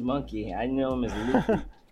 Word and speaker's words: Luffy: Luffy.